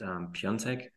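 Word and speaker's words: Piątek: Yeah, so my name is Lucas Piatek, which is a Polish name. So initially you would pronounce it Piontek.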